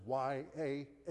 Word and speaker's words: Yahweh: And in time, the English influence added the vowels of A and E so that it comes out as YAHWEH. We pronounce it something like Yahweh.